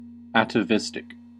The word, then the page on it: atavistic: American English